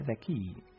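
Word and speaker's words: the key (ذكي): Se pronuncia the key.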